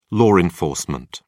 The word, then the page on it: law enforcement: British English